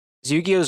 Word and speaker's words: Yu-Gi-Oh!: Yu-Gi-Oh! Is Japanese, and we say it the same as they do.